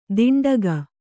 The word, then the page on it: ದಿಂಡಗ: diṇḍaga